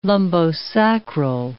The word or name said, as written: lumbosacral